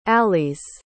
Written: alys

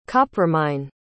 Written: coppermine

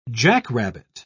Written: jackrabbit

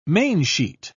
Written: mainsheet

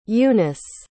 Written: eunice

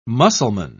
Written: muscleman

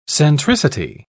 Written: centricity

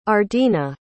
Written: ardena

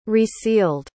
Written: resealed

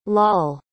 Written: Lall

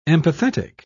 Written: empathetic

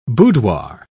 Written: boudoir

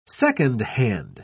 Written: secondhand

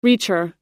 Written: reacher

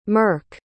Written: merc

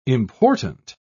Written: important

